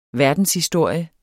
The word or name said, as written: verdenshistorie